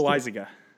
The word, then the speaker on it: Loaisiga: Is that, am I saying that right? – I think it's Loaisiga.